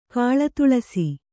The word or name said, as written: ಕಾಳತುಳಸಿ